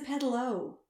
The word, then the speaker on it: pedalo: Oh, she was, because apparently, Aaron, it's not a pedaloo. It's a pedalo. – It's pronounced pedalo.